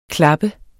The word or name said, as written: klappe